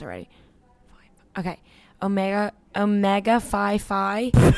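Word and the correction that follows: Psi Phi: It's Omega Sci-Fi. – Like, okay, sci-fi. I was right with the one.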